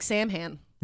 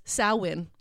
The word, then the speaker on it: Samhain: So Samhain is spelled Samhain. And that is your first lesson in Gaelic. That looks like Samhain, but it is Samhain.